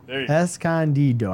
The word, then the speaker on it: Escondido: Congratulations to Chris Gleed from Escondido. I said it right today? – Escondido. – Escondido.